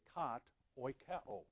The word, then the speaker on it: kat oikeo: And it's pronounced kat. You drop the last A, kat oikeo.